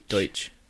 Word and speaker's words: Deutsch: The actual correct pronunciation is Deutsch.